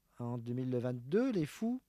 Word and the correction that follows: Les Foules: Les Foules, ouais. – Ouais. Mais comme ça, entre parenthèses, on le prononce ou pas? – Bah ouais, du coup, c'est une sorte de jeu de mots... Ouais, Les Foules.